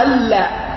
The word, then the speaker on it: أن لا: هنا تختفي وجوبا، تختفي وجوبا، أن لا إله إلا الله تكتب نعم لكن ما تنطق، وإنما تنطق ألا، أين النون؟ أضغمت وجوبا، كما ذكر ذلك ابن هشام في كتاب موصل الطلاب إلى معرفة أحكام الإعراب.